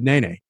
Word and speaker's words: Nene: Tell me how you say your name. And he just said, Nene.